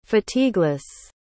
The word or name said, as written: fatigueless